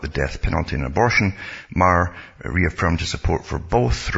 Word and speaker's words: Maher: I think it's Maher. I shouldn't pronounce it Maher or Meyer, but it's Bill Maher it's pronounced.